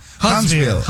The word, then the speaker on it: Huntsville: Huntsville, Chiri. ¿Sí lo dije bien, o no? – Sí, sí, sí. Huntsville.